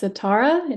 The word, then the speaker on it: Sitara: So my name is Sitara. It's spelled like the instrument with an A at the end, Sitara.